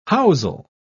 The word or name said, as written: housel